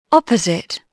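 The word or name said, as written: opposite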